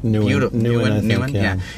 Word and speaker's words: Nouwen: Henry Nouwen. Is that how you say his name? – Nouwen.